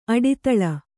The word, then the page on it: ಅಡಿದಳ: aḍidaḷa